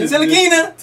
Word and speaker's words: Ntilikina: I am all for having Frank Ntilikina, is that how you say his name? – Ntilikina.